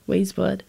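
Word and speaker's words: Weyes Blood: Am I saying that right? – Yeah. – Okay, Waze Blood.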